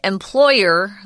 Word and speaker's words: employer: Notice the emphasis is employee. An employer, the emphasis is on the second syllable.